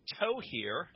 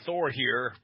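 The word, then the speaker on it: Thohir: It's pronounced toe here. – Okay. – I've been saying Thor here,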